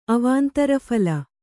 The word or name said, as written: ಅವಾಂತರಫಲ